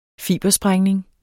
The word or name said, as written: fibersprængning